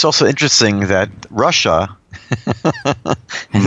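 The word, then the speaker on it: Russia (רשע): Like at the Passover Seder, there's the son that's the Rasha, which really is pronounced Russia.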